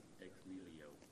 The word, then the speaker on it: ex nihilo: It says, he uses a Latin phrase here, and I hope I get this right. If not, I'm going to ask to pronounce it for me. It says, Latin phrase to describe God's work of creation is creatio ex nihilo. – Ex nihilo. – Ex nihilo. There we go.